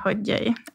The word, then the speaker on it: Hodgyai: navnet mitt er Reka Hodjøy, eller Hodjøy Reka, fordi det er sånn som man uttaler det på ungarsk.